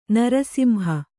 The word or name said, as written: ನೃಸಿಂಹ